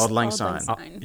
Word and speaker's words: Auld Lang Syne: It's Auld Lang Syne.